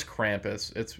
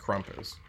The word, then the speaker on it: Krampus: No, it's Krampus. – No, I'm just saying, I think Dwight from The Office... – I think it's the accent that he's using, yeah. – Though. It's not actually pronounced Krumpus.